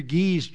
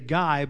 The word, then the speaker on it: Gui: One of the last services we were in was at Pastor Guy's church. It's spelled G-U-I, and we would say Guy, but it's French. It's Guy.